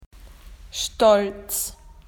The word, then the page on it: proud: Australian English